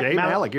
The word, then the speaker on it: Jay Malick: Am I saying that right? – Jay Malick, you're saying it exactly right.